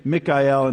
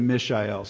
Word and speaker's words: Mishael: And just a short little recall from last week, I was saying Mikael, and it was actually Mishael. So no major heresy there. Both names actually mean about the same. I was just saying it wrong last week.